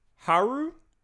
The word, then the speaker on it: Haru: Haru and Yusuke? – Oh my god. – Did I pronounce any of those correct? – Yes, that was perfect.